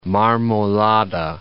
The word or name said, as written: Marmolada